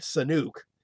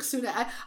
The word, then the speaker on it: Sunak: I do know that President Biden's Rashid Sunuk is not correct. – No. – Oh boy. See, again, you should probably know how to pronounce the name. – Sunuk Sunak. That's like, Sunuk Sunak.